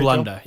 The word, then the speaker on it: Frölunda: Frölunda of the SHL? – Frölunda, yeah.